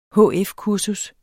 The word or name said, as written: hf-kursus